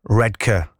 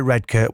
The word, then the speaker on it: Redcar: Redcar. I say Redcar. When people say red car, it makes me want to blow a gasket.